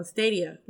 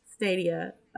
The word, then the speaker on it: Stadia: Well, Stadia. Let me say it right. Stadia.